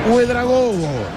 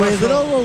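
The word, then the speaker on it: Ouédraogo: A Gira, Bama, Diallo, Dao, Chameogo, Dumbia, Cante, Uedragogo. Porque la OU en francés se pronuncia como U. Guatara, Cam y Diarra. Ahí está. Los de Burkina Faso. – Uedrogo, Guatara, Cam, Diarra.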